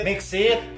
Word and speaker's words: MXit: MC Mixit Mixit MC MC MXIT MXIT iya, bacanya Mixit kan iya, iya.